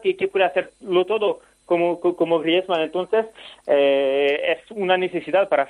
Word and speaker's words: Griezmann: Me gustaría que tú dijeras cómo se dice en francés Antoine Griezmann.